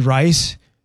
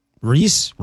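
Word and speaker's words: Rhys: Matthew Rice. What did I say? Reese? Is that how he says it? He's got, I think he's from Wales. And it's spelled R-H-Y-S. I think he pronounces it Reese, Rise.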